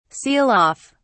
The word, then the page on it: seal off: British English